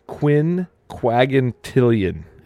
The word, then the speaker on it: quinquagintillion: It's Quinquagintillion is what it's pronounced as.